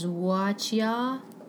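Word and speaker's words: Wuxia: But the first one, and I think the pronunciation is Wajia, which literally means martial heroes, and it's a genre of Chinese fiction concerning the adventures of martial artists in China.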